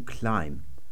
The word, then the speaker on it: climb: Da haben wir nämlich das gleiche Wort to climb. Da schreibt man zwar das B noch, aber man spricht es nicht mehr.